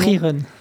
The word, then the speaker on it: Frieren: Frieren.